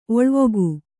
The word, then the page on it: ಒಳವುಗೈ: oḷavigai